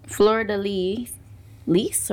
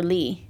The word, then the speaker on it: fleur-de-lis: Fleur-de-lis or Lee? – I think Lee. – Okay. – Because if it's French, you typically don't pronounce the S at the end.